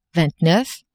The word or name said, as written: vingt-neuf